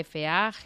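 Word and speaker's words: Fageda: que se escribe, que lo he dicho yo raro al principio, Faxeda.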